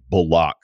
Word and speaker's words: Bullock: The up fake that he had was on Reggie Bullock, who, again, changed the pronunciation of his name, which caught me off guard when I started hearing people say Bullock, like some Bond villain.